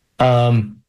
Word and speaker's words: Quercetin: Quercetin.